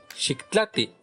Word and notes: xiktlati: Náhuatl

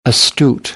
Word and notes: astute: American English; t

